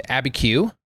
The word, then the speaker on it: Abiquiu: And then she directed Abiquiu, if that's how you in fact pronounce it.